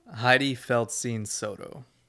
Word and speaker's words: Heidi Feldstein Soto: Heidi Feldstein Soto.